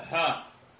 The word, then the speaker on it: حاء: وقيل هي حاء من حائل لأنها تحول بين إسنادين فلا تكون من الحديث كما قيل بذلك، ولا يلفظ عندها بشيء، تلفظ هكذا حاء. وقيل هي رمز إلى قولنا الحديث، وإن أهل المغرب كلهم يقولون إذا وصلوا إليها الحديث، والمختار أنه يقول عند الوصول إليها حاء ويمر.